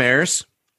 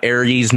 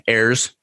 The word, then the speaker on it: Ayres: So I'm Sam Ayres. Not Aries, not Ayres, not Ayres, Ayres.